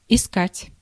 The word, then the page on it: искать: [ɪˈskatʲ]